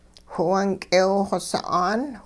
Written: whiwung k'eew-xw sa'aan

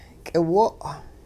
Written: k'iwo'

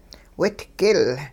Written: widgil

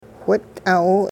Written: whit'aaw

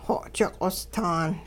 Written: xo'ji-q'ostaan

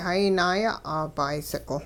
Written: hayi naaya'aa bicycle

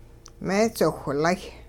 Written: meedziwhlay

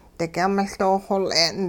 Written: digyung-miL doo-xol'e'n

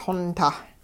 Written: xontah

